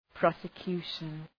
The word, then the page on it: prosecution: ,prɒsə’kju:ʃən